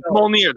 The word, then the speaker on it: Mjolnir: It's like Majorna or something. – Mjolnir. That's the pronunciation. It's Mjolnir.